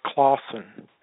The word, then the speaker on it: Klassen: And I'm going to read from Nature's Eternal Religion, which was written by Ben Klassen, and it was actually his last name is pronounced Clausen. I just wanted to say that. He pronounced it as Clausen.